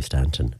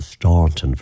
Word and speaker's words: Staunton: I have a feeling that that town may well have been named after somebody from County Mayo, because the traditional pronunciation of that name here in Mayo would be Stanton, a silent U, even though the more posh version of it would be the Staunton version of it, which a lot of RT folks and all of those like to use when they're talking about Cora.